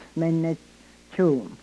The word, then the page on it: menechuum: Yurok